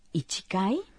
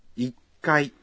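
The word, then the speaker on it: Ikai (一階): Uno es ichi, de modo que será ichikai. – No, la respuesta correcta es... – Ikai. – Ichikai es un poco difícil de pronunciar, por eso se dice...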